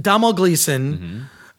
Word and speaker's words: Domhnall Gleeson: Domhnall Gleeson.